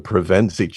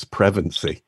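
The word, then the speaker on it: Pevensie: But I have to confess, I learned that for 4 decades, I have been mispronouncing the Pevensey children's last name as Prevency. So thank you. – Oh. – I'm blushing, but I appreciate the correction.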